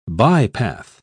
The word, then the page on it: bypath: British English